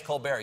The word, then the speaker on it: Colbert: Yeah, it's Colbert. You don't pronounce the T.